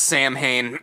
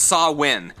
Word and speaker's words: Samhain: And it's spelled Samhain, if you wanted to look it up, but it's not pronounced Samhain, it is pronounced Samhain.